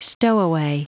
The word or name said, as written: stowaway